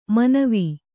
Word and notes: ಮನವೆ: manave

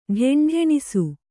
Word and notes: ಢೆಂಢೆಣಿಸು: ḍheṇḍheṇisu

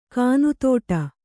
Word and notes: ಕಾನುತೋಟ: kānutōṭa; kannada

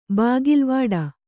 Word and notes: ಬಾಗಿಲ್ವಾಡು: bāgilvāḍu

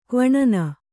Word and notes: ಕ್ವಿನೀನು: kannada

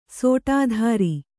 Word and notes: ಷಡಾಧಾರ: ṣaḍādhāra

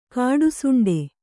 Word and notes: ಕಾಡುಸೊಂಡೆ: Kannada; kāḍu soṇḍe

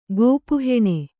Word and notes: ಕಪ್ಪೆಹುಣ್ಣು: kappehuṇṇu

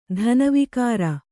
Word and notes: ಧನವಿಕಾರ: kannada

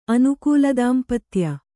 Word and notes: ಅನುಕೂಲದಾಂಪತ್ಯ: kannada

